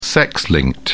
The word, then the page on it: sex linked: British English